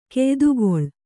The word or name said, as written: ಕೆಯ್ದುಗೊಳ್